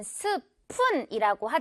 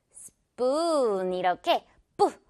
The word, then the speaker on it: spoon: 역시 우리는 스푼이라고 하지만 한국식 발음이고요. spoon 이렇게 뿌 완전 된소리 발음으로 내줘야 자연스러운 영어 발음이 됩니다.